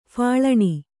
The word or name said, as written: ಫಳಣಿ